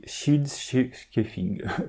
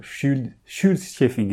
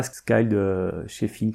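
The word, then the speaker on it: Scyld Scefing: Donc apparemment, vieil anglais, ça se dit Shield Scheffing. Shield Scheffing, et non pas Skald Scheffing, comme j'ai pu le dire.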